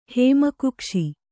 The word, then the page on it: ಹೇಮಕುಕ್ಷಿ: hēma kukṣi